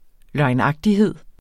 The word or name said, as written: løgnagtighed